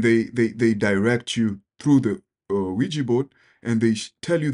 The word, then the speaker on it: Ouija: Ouija board.